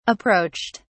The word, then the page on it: approached: British English